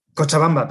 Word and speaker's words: Cochabamba: I'm trying to get the pronunciation. Cochabamba. – Cochabamba, very good.